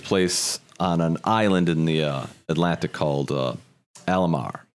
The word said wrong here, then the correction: Al-Amarja: Or Alamarja. Marja. I don't know if I'm even pronounced it. It's Al-Amarja. Alamarja.